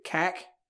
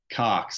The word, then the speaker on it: Kark: I'm going to pronounce it wrong, but is it Kak? – It's Kark. So he says it's like Clark, but without the L.